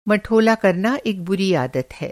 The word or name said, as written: मठोला